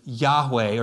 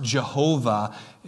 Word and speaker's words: Yahweh: And uh, so that name, you know, I am, is Yahweh, or sometimes pronounced Jehovah if you've heard that before.